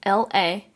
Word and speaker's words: Mikaela: You can find me online on everywhere, basically, but on Twitter, it's just my name, Mikaela Caron, so that's spelled Mikaela, then two underscores, and then my last name, Caron, because I couldn't get my name without underscores.